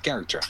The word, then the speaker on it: character: Nee, niet karakter, gewoon character. Character. Ja. – CH spreek je uit als een K.